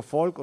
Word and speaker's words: Folch: No sé si Falk se dice bien dicho Falk o se dice Falch o se escribe F-O-L-C-H. Se dice Falk. – Falk, sí, sí. – Falk, vale. Es